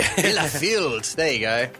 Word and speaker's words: Bielefeld: Well, a German town, I'm going to try and pronounce it, Bielefeld. – Oh, yeah. – Simo, you have German ancestry. Did I pronounce that correctly? – Yeah, I don't know, maybe. – There you go. Do you want to have a go at pronouncing the name? – No, I reckon you got that right.